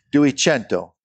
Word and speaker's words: Duecento: Could you do us a favor and give us the correct pronunciation? I'm not saying Barry was wrong. – Oh, let me try. – Oh, this will be fantastic. – Duecento. – All right. Actually, that was pretty good.